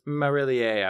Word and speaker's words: Marillier: Yeah, Garant Marillier, I would say, is how you say that name.